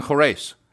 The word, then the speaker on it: Khurais: Bgeg and Khurais.